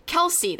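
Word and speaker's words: Kelsi: Get your first shipment free when you use our promo code Kelsey.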